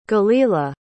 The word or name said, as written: gelilah